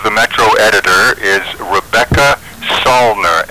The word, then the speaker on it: Salner: The name again is Rebecca Salner, S-A-L-N-E-R.